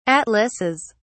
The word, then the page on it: atlases: British English